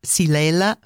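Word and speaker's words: Xylella: It's actually spelled with an X, but it's Silella fastidiosa.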